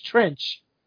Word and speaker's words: Treach: Trench. It's spelled treach, but it's trench.